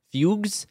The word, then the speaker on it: fugue: In some cases, terrifying visions were followed by fugus, which is an old term for, like, fugues. It says fugues. – Fugue. It's pronounced fugue.